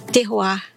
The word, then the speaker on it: terroir: Mas seguindo aqui na nossa programação, o que, que é o terroir? É assim que fala? Tô falando certo? Tá falando certo.